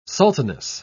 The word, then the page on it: sultaness: British English